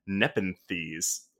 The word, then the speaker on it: Nepenthes: And until our next micro queers, we can cross out Nepenthes.